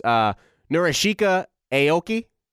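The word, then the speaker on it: Norichika Aoki: Am I saying this right, Alex? Narashika Aoki? – Yeah, that's pretty good.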